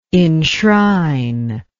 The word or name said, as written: inshrine